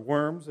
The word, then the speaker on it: Worms: They met with the German Lutherans in Worms, or Worms, if you want to pronounce it our way.